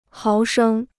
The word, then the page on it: 毫升: háo shēng